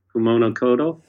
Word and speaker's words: Kumano Kodo: So we're looking at the Kumano Kodo Trail. Is that the right way to pronounce it? – Kumano Kodo.